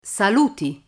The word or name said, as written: saluti